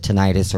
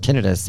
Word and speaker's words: tinnitus: Well, first of all, the reason I even started all this was because I have, you know, depending on how you want to pronounce it, tinnitus or tinnitus in my ears going on three years.